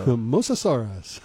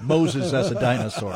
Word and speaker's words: Mosasaurus: Love a good pronunciation of a Mosasaurus. – Of Moses as a dinosaur.